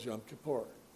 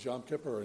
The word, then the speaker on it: Yom Kippur: Yom Kippur. It's pronounced both ways, Yom Kippur and Yom Kippur.